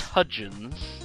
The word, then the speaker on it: Hudgens: I thought it was like Vanessa H- Huygens or something, or Hy- Hy- I thought the D what? was silent.